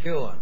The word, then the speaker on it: McKeown: And Judge McKeon easily dispatched and distinguished. – McKeown. – I'm sorry, McKeown.